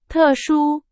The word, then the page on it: 特殊: Chinese